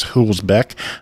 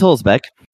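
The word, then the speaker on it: Hülsbeck: Hulsbeck. I'm sure I butchered that. Play through the history. – It's Hulsbeck. – Yay. – I'll see it. – You got it right.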